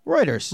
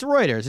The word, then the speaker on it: Reuters: Well, next story of the week comes to us from, how do you say it, Reuters? – Reuters. – Reuters? – It's Reuters. It's spelled Reuters.